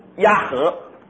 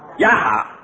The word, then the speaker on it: 亚哈: 那后来他篡位成为国王，那这个案例啊，就跟他打交道啊，就叫他的儿子亚和，亚和对不对？亚哈，OK，亚哈。